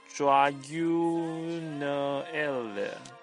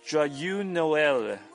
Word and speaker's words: Joyeux Noël: By the way，附上的明信片是我自己尝试着电脑绘图的圣诞贺卡，上面的黄字是法文的圣诞快乐，发音大概是 Joyeux Noël。 – 什么 Joyeux Noël，那么最后祝马克玛丽圣诞快乐。